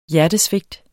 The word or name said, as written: hjertesvigt